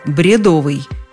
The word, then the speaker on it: бредовый: Тут пришло время мне задуматься, потому что сама я произносила это слово по-другому – «бредовые». Благо, словарей много, хороших и разных, докопаться до истины не проблема. Наш первый источник – словарь ударений Резниченко. Что мы там находим? Ура, мой вариант – бредовый. И никаких сомнений.